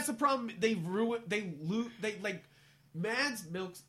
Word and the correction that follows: Mads Mikkelsen: How do I say his name? Mads Mikkelsen.